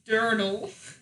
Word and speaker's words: Durdle: and the chick's name was Jesse Durdle. Did you see that? Rhymes with turtles.